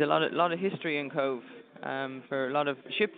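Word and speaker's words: Cobh: I'm from a place called Cove in County Cork. – Is that how you pronounce it? – That's right, yeah. – Cove.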